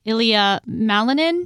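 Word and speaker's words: Ilia Malinin: And I might say the name wrong. Is it Ilya Malinin? – Yes.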